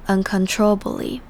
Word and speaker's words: uncontrollably: 接着我们来看 uncontrollably 这个字，要注意的地方是这边 control 的 con 的 o 是读成 a 的感觉，在这整个字里面听起来像 control，而最后面的 ably 这边的 a 也是念成 a 的声音，所以整个字是 uncontrollably。